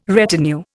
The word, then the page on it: retinue: Indian